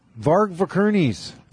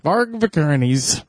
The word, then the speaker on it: Varg Vikernes: Vikernes, thank you. Is that how you say it? – I don't know. – Varg Vikernes. – Varg Vikernes.